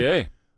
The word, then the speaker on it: Bossier: And so this gentleman was doing that, and he was in Louisiana, Bossier City. Am I saying that correctly? Mm-hmm. Bossier City. – Bossier.